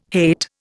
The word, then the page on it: hate: Indian